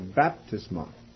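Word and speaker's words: baptisma (βάπτισμα): Or actually, the emphasis in that case is on the first syllable, baptisma.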